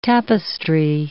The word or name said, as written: tapestry